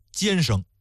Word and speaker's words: 监生: 赵乡绅呢读书不行吧，却附庸风雅，花了大价钱捐了一个国子监的监生。你看啊，国子监读书的不叫监生，叫监生，同一个字儿，为什么非得区分开来呢？本来就应该叫监生，但是呢他跟那个贱民就会谐音了，所以说得念成叫国子监的监生。